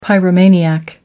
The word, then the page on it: pyromaniac: American English